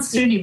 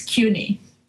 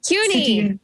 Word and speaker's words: CUNY: It's not SUNY, by the way. It's CUNY. – CUNY. – Yes. – I've gone back and forth on that historically, where I've pronounced it correctly, and then I convince myself that I'm wrong. – No problem. One is State University, the other one is City University.